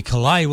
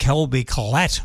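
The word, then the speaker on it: Colbie Caillat: That's Colby Collet. Well, I used to call it Colby Collat, but no, it's Collet is how you pronounce it.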